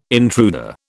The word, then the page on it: intruder: British English